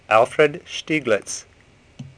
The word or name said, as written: Stieglit